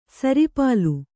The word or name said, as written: ಸರಿಪಾಲು